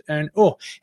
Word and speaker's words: os: And os is pronounced un os.